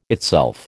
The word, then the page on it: itself: American English